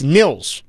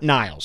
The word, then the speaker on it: Nils: It's Nils. Nils Hoglander, not Niles.